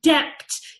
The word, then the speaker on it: debt: debt, when he should pronounce debt, D-E-B-T, not D-E-T.